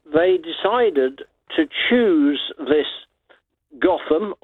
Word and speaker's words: Gotham: They pronounce it Gotham in America. The Americans can never pronounce anything correctly.